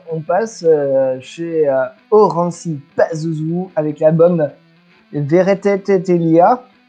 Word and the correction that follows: Veretelia: Non, pardon, j'ai mal prononcé. Veretelia, Elia, 2016 chez Dart Records.